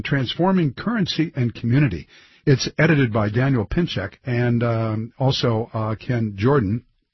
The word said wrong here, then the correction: Pinchbeck: Daniel Pinchbeck, let me say his name correctly.